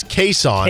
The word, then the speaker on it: Kason: So the first one was Kason, now it's Kason. – Kason. – With emphasis on K is his preference and what his mom called him. Kason. – He also said Kason, pronounced like Jason or Mason, is acceptable. So you can call him Kason, Kason, or Kason.